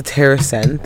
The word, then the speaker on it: TerrAscend: But anyways, so let's talk about the TerrAscend thing. Mm. – I did work for them at one point. Yeah, or not TerrAscend. It rhymes with Marisend, allegedly.